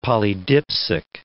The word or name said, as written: polydipsic